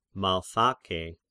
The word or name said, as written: Malthake